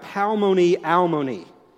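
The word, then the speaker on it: palmoni almoni (פלוני אלמוני): The word in Hebrew is pronounced something like palmoni almoni. It's these meaningless rhyming words put together to make sort of an expression or a phrase.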